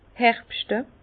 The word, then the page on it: herbschte: Bas Rhin; Herrlisheim